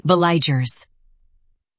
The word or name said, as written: veliger